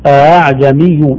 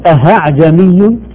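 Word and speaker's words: ءأعجمي: أعجمي بين الهمزة وبين الألف. لا نقول أهعجمي ولا أعجمي بالتحقيق.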